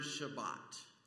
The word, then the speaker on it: Shabbat: Sabbath is the way we have pronounced this Hebrew word Shabbat.